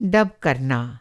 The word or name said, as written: डब